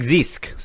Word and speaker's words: Zisk: So I will say this episode was written by Sean Jablonski and directed by good old Craig Risk, uh, Craig Zisk. Sorry, I can't even pronounce his name because it's a different letter than I went for.